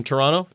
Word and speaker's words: Toronto: By the way, when you're driving sort of along the Lakeshore Drive there in Toronto, notice how I said it properly, Toronto, not Toronto, Toronto.